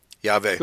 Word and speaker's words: Yahweh: And eventually, I felt confident that the name of Father Yahweh was, as we spell it, with the W-E-H. – Yeah, but it's pronounced like a V, because the letter W in English, in any language, did not exist until very late in history. It was, and by the Germans, it's pronounced like a V, Yahweh.